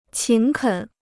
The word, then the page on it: 勤恳: qín kěn